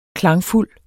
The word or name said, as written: klangfuld